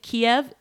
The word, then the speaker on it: Kyiv: She lives in Kyiv now. By the way, Kiev is the Russian pronunciation and Kyiv is the Ukrainian pronunciation. So we're going to call it Kyiv here.